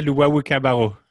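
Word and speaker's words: Luwawu-Cabarrot: Timothée Luwawu Cabarrot. – Luwawu Cabarrot.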